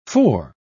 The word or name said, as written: fore